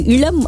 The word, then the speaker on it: இளம்: இல்ல, இந்த எழுத்து வரும்போது முன்னாடி வந்து இந்த இஇ ஏஏ அந்த மாதிரி எழுத்து வந்து சேர்ந்தா அது இளம் அப்படின்னு நம்ம வந்து உச்சரிக்கணும்.